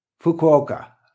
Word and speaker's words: Fukuoka: Fukuoka.